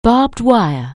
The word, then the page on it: barbed wire: anglais